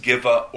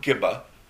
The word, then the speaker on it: Gibeah: As the two, the Levite and his concubine, are traveling, they happen upon the city of Gibeah, in Hebrew, could be pronounced Giva or Gibba, where they were cautioned not to stay in the town square, like the angels who visited Sodom.